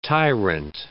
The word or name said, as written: tyrant